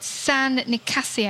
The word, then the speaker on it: San Nicasio: They're called San Nicasio. I've totally pronounced that wrong. – No, that's the traditional pronunciation there.